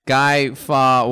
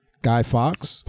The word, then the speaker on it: Guy Fawkes: It looks like if Guy Fawkes was a cholo is what it looks like.